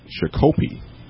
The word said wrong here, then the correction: Shakopee: Yeah. – And the rest of us in the class, who are all, you know, most of us are Minnesotans. – Giggling. – So we're just kind of giggling and kind of snickering, and he kind of looked up like, what? And the teacher was like, it's Shakopee.